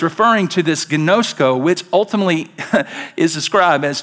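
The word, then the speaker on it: ginosko (γινώσκω): You can see the G-I-N-O-S-K-O, that's pronounced ginosko. Ginosko.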